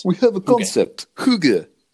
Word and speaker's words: Hygge: Hyg. – Oh yeah. No. Yeah, yeah. – Hygge. – Yes, Hygge. That's how you say it. It's spelled like hyg.